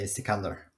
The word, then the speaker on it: Sikander: And the first question to our one and only producer is, how do we say your name? – Yeah, you can say it whatever way you like to. – Well, we'll say it the way you say it. – I say Sikander. – Okay, so we got our producer, Sikander. – I can't pronounce it like that. Okay. I just want to say it. – Just think of knäckebröd and then Sikander.